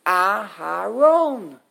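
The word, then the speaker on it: Aharon (אהרן): And we have to hear it, we make it into a patach. Once you make it into a patach, so pronounce it like a patach. Aharon.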